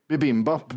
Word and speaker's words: bibimbap: Bimbapp. – Ja, exakt. – Nej, för det är ett i där i mitten också. Bibibimbap är det, något sånt där jättejobbigt. – Bibimbap.